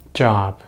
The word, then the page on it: job: American English